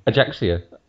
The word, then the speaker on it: Ajaccio: Ajaccio. – Ajaccio. Have I said that right or wrong? – Just about right, thankfully.